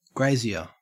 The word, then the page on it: grazier: Australian English